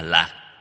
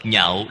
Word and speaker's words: nhạo: Nhảo, cái chữ này đọc trại âm, không đọc là lạc. Lạc nghĩa là vui vẻ. Đọc nhạo là đọc thanh khứ, nhạo là yêu thích.